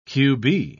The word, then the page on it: qb: British English